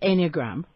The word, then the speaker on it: Enneagram: I wasn't sure whether is it pronounced Enneagram. – That's correct, yes, no, you're correct.